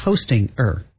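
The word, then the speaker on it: Hostinger: But it's hosting-er. – Yeah, that's just how you spell it. – But it's like hosting, and then you put an ER at the end.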